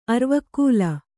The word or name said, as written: ಅರಿವೆಕಾಲು